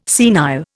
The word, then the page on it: senile: Indian